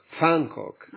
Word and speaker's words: Van Gogh: E Van Gogh come si pronuncia? Fancock. Si chiama Fan. Fancock.